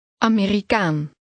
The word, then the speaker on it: Amerikaan: Amerikaan. Kaan. Kaan. Ameri. Ameri. Amerikaan. Amerikaan. – Note that the stress is on the last part of the word.